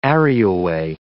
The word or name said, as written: areaway